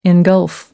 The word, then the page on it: engulf: American English